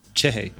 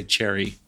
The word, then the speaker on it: Cerri: I want to add like too many syllables to it. – Uh-huh. – It's spelled C-E-R-R-I. So I wanted to say cherry, but because I don't have a good Brazilian accent.